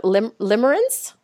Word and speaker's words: limerence: Yeah, you talk about something in your book, a term that I had never heard before, and it's, and I don't even know if I'm saying it right, but limerence, is that right? – Yes, you're saying that right.